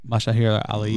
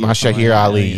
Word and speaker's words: Mahershala Ali: I think it's Mahir Ali, isn't it? – Mahershala Ali. – Mahershala Ali, yeah.